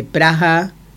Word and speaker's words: Praha: En checo se escribe P-R-A-H-A y la H suena como una J aspirada, Praha.